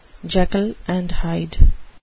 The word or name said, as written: Jekyll and Hyde